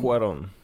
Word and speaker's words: Cuarón: Alfonso Cuarón. – Cuarón. – Miałem to wygooglać, żeby nie robić piochy, ale będę... – Co, jego Cuarón. Wymowy, jego nazwiska? – Tak.